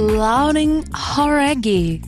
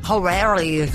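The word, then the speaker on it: Lauren Jauregui: É Lauren Horaghi. Talvez você já tenha ouvido falar dela, ex-integrante do grupo Fifth Harmony. – Horaghi. – Horaghi. Então a gente estava falando errado, cara.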